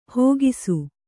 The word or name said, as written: ಹಾಕಿಸು